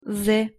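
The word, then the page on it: з: Russian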